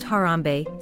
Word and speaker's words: Harambe: Then the cup is passed to each family member, who sips while everyone chants Harambe, Swahili for "let's pull together," and pronounced Harambe, seven times.